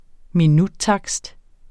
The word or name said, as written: minuttakst